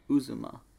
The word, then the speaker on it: Uzomah: Uzma. – Uz, Uzomah.